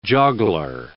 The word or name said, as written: joggler